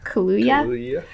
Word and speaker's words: Kaluuya: How do you say his last name? Kaluuya? – Kaluuya.